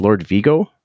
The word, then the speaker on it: Lord Vigo: So your other band, forgive me if I'm pronouncing it wrong. It's Lord Vigo. – Yeah, it's perfect. Right.